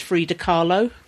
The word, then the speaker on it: Frida Kahlo: There's a whole section of it with the artist Frida Kahlo. – Oh, yes. – Is that how you pronounce her name? – Yes. Yeah.